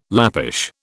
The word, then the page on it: Lappish: British English